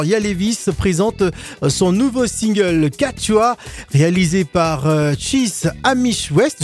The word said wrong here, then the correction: Chris Amy West: Je ne sais pas si j'ai bien prononcé. Il va nous le dire tout à l'heure. Est-ce que je l'ai bien prononcé ? – Non, c'est Chris Amy West.